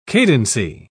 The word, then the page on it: cadency: British English